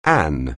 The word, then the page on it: an: anglais